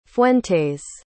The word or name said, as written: fuentes